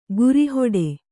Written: ಗುರಿಹೊಡೆ

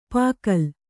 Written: ಪುಕ್ಕಲಿ

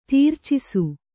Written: ತೀರ್ಚಿಸು

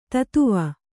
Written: ಧತ್ವ

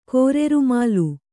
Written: ಕೋರೆರುಮಾಲು

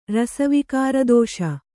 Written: ರಸವಿಕಾರದೋಷ

